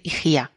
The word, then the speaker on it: Higía: la hemos visto escrita con EI y GIA, pero el diptongo griego EI se pronuncia I, de modo que en español nosotros debemos decir IGIA.